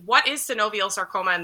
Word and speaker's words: synovial sarcoma: You are a synovial sarcoma cancer survivor.